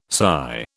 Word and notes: SY: British English

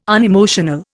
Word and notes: unemotional: Indian

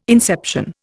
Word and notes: inception: Indian

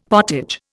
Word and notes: pottage: Indian

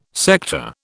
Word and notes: sector: British English

